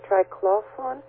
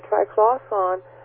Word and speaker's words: triclosan: The other thing I wanted to add was a follow-up from when I was talking about the antibacterial product that contains, I think the way you pronounce it is triclosan, T-R-I-C-L-O-S-A-N.